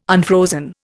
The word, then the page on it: unfrozen: Indian